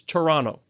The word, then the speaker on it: Toronto: You don't put the second T. Ask anybody. It's Toronto.